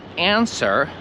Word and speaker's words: answer: Ale ja byłem zaskoczony, że ludzie wymawiają answer z tym W. To jak widzisz, słowo answer ma literę W.